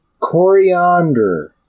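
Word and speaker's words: Koriand'r: Princess Coriander, but not spelt like coriander, like the spice. – Yes. – It's spelled with K's and an apostrophe before the last R. – But I do believe it's still pronounced the same. Or at least I've always pronounced it that way.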